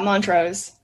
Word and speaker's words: Montrose: Is it Montrose Chemical Corporation? Is that how you say it? – Yeah, Montrose.